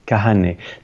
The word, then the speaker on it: Kahane: Kahane? – Kahane. Ça s'écrit K-A-H-A-N-E.